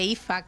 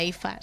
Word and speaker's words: Aoife: El nombre Ifa, que se pronuncia Ifa, se escribe Aoife.